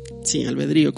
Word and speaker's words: albedrío: Sí, albedrío, con acento en la I.